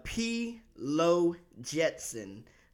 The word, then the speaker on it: P. Lo Jetson: It's P. Lo Jetson.